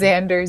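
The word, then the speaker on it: Zander: And my last name is Xander, Z as in zebra, A-N-D-E-R.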